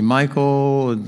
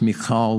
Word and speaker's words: Michal: Some people say Michael. I think in the Hebrew it would probably be pronounced Michal with one of those guttural H sounds.